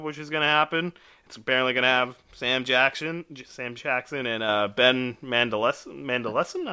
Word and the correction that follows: Mendelsohn: I don't really know how to pronounce his name. – Ben Mendelsohn, I believe. – Mendelsohn. Mm-hmm. Yeah, that looks right.